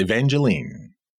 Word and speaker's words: Evangeline: But another thing he did was he illustrated a piece, a book called Evangeline, I believe is how you might pronounce it. – Yes, Evangeline.